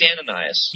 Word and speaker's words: Ananias: Is Ananias the proper way to pronounce this other fellow's name? Or am I thinking of someone else? The guy that was sent to Saul. – It's Ananias.